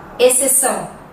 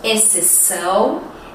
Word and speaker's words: exceção: Sim, às vezes ela é silenciosa. Quer ver? As palavras exceto, exceção uhum, e excelente. Vamos repetir? Exceto, exceção, excelente. Sim, se escrevem com X, mas nós não pronunciamos ele, ok?